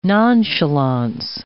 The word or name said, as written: nonchalance